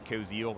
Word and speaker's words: Koziel: If you just go to YouTube and type in my name, Dave Koziel, that's spelled K-O-Z-I-E-L, I have literally 50 to 100 videos with step-by-step tutorials teaching you how to publish ebooks and make money by doing that.